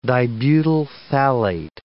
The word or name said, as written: dibutyl phthalate